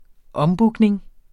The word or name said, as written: ombooking